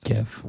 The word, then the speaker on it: GIF: Okay, so honest to goodness, do you guys pronounce it GIF or JIF? – I pronounce it like it's supposed to be pronounced. – GIF. – Which is GIF. Because it has a G, not a J.